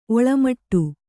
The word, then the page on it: ಒಳ್ಮಿಡಿ: oḷmiḍi